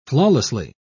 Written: flawlessly

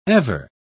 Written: ever

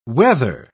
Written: wether

